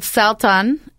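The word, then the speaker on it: Salton: And speaking of good life, in front of me, I have three glasses of Brazilian wines, all from a winery called Saltan. Am I saying that right? – That's correct.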